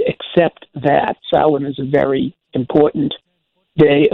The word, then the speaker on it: Samhain: Now, that is spelled Samhain, but it's pronounced Samhain. It's a Gaelic word, it's a foreign word, so the M and the H are silent, and it comes out sounding Samhain.